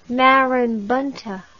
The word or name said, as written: marabunta